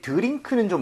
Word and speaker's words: drink: 그래서 드링크가 아니라 이 모양 좀 모아서 drink 이렇게 가는 게 더 자연스럽죠.